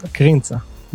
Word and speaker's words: Krintza: Almost, Krintza.